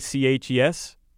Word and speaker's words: kolache: He ate 44 cherry kalash. Kalash, is that how you pronounce it? Kolaches. – Oh, those kolache. That's kolaches.